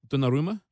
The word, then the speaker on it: Donnarumma: How do you pronounce it? Donnarumma? – Yep.